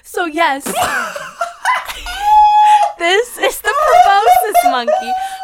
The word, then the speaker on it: proboscis: But I believe it is pronounced the proboscis monkey.